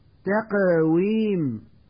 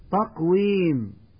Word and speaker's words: تقويم: تقويم، ويقول من يخطئ تقويم بتفخيم حرف التاء وعدم قلقلة القاف، خلق، ويقول من يخطئ خلق بتفخيم حرف اللام، الأكرم، ويقول من يخطئ الأكرم بتفخيم الهمزة.